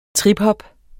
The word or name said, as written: triphop